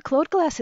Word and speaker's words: Claude Glass: I always pronounce it Claude Glass. A Claude Glass is a black mirror.